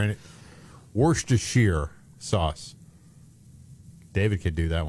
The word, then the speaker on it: Worcestershire: Well, I heard it Worcestershire, like that's how you're supposed to pronounce it.